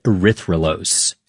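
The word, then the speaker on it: erythrulose: And they scanned it for radio signals of different molecules, which is how they found a four-carbon sugar called erythrolose.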